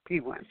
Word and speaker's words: P-WIN: Okay, P-WIN. – So okay. – It's P-WIN. It's short.